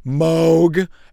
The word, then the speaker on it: Moog: It's just that person who goes, Moog, it's pronounced Moog.